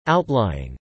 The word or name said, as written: outlying